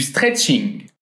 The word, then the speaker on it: stretching: Notez bien ici que le mot est en anglais, mais on le prononce d'une façon très française, du stretching.